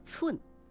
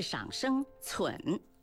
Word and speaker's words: 忖: 有不少朋友读右半边寸，不对，这个字的正确读法是赏声寸，